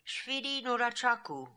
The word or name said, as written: Shvidi Noora Chakoo